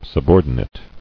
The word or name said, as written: Subordination